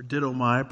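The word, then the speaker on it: didomai (δίδωμι): The Greek word for given here is didomi, or didomai, probably is better pronunciation.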